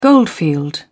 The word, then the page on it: goldfield: British English